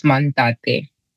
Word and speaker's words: Mantate: So my name is pronounced Mantate. – Mantate. – Mantate. Okay. Yeah. And a lot of people say Mantate. So as long as the Ntate comes in, because Ntate is a particular, you know, part of the name.